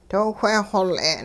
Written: doo whe xol'e'n

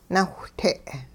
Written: nawhte'

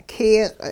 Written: kiyiq'

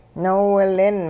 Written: noowilin

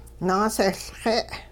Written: naasehLxe'

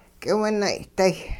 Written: k'iwinay'day

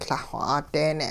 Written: Lah-xw 'a'deene'

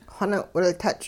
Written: xoning'-wiltuch'

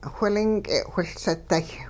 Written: whilink'e whiLseday

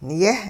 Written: yeh-

